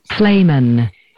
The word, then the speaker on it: Flehmen: Flamen. Flamen. Flamen. – It is spelled F-L-E-H-M-E-N.